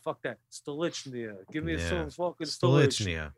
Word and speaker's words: Stolichnaya: That's why I'm drinking, how do you pronounce the name of this? – Stolichnaya. – Thank you. Stolichnaya.